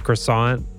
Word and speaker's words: croissant: croissant. – It's croissant.